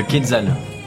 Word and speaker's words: Quetzal: Le Quetzal. – Ah oui! – On peut le prononcer à la française.